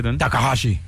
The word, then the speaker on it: Takahashi: Su nombre es Takahashi, así metale, sí, la pronunciación.